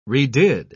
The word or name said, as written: redid